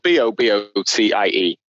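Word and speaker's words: bobotie: How do you pronounce that then? – Bobo tie. Bobo tie.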